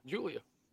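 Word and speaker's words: Giulia: G-I. It gives that J sound. You, Julia. Julia.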